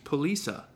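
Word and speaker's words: Polyphia: Number three, Polisa, I think is how you say it.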